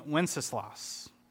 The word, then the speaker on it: Wenceslas: And so Neil also, for those of you interested, originated the words to good King Wenceslas. I think I said that right. Did I say that right, Erica? I didn't say that right, did I? I did say it right.